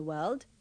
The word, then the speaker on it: world: Don't pronounce the R in world and the E in village. – There are 5 continents in the world.